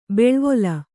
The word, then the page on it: ಬೆಳ್ವೊಲ: beḷvola